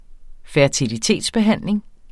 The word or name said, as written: fertilitetsbehandling